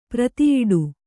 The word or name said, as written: ಪ್ರತಿಯಿಡು